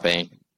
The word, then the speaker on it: Payne: And so we joked about the struggles that our interior offensive line had against Payne. Am I saying his name? Yeah, okay. – Jerron Payne. – All right, I am saying his name right.